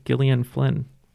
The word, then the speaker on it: Gillian: This is Chicago native Gillian Flynn. – Good job, and good job on the pronunciation of Gillian, too.